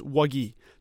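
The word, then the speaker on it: Wague: The other one being sophomore forward Muhammad Wagi. And I'm going to acknowledge it now. The way Wagi is spelled blows my mind. It's W-A-G-U-E, so it looks like Wag or something like that. It's Wagi. So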